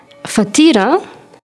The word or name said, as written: fatira